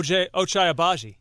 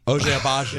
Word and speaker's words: Ochai Agbaji: Oh, Ochai Agbaji. – Ochai Agbaji.